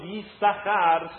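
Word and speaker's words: Yissachar (יששכר): Yissachar, embora nós falamos Yissachar, se escreve Yissachar. Yud, Shin, Shin, Chafres. A gente lê só Yissachar, mas tem dois Shin aqui.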